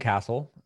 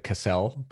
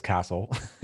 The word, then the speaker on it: Casel: I'm Brian Casel. Most of the world pronounces it Casel, but it's Casel, even though it's not spelled that way.